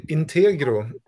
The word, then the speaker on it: Integro: Och det heter, eh, kan jag uttala det då? In ja, det... – Integro. Ja, exakt. 149 kronor.